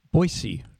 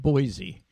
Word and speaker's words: Boise: And people should note that you said Boise, not Boise. It's yes. – pronounced with an S, not a Z.